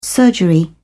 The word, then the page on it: surgery: British English